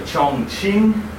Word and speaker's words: Chongqing: Q is ch, so Chongqing.